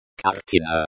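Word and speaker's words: картина: Other variations of kartina. Kartina. Kartinu. Kartini, pictures, plural pronounced, kartini. Kartinku. Kartinku, small picture, diminutive form, pronounced, kartinku. The stress always falls on the second syllable of the word. Kartina. Kartina.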